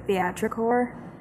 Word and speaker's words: theatricore: Well, I've seen it be like, correct me if I'm saying it wrong, but is it theatricore? – Sure.